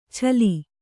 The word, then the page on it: ಛಲಿ: Kannada